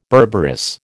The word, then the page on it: berberis: American English